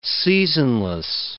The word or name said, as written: seasonless